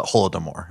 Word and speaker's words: Holodomor: That's the mass famine called the Holodomor, if I'm pronouncing it properly.